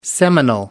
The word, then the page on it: seminal: American English